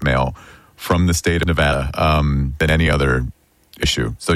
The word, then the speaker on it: Nevada: was the correct way to pronounce the state that Las Vegas is in, because a lot of politicians would say Nevada. – Wrong. – And that is wrong. – It is Nevada. And I had to train myself to say Nevapple so I could pronounce the A right.